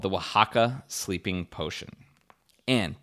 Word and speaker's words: Oaxaca: So if you ever see that OAXACA, that is pronounced Oaxaca,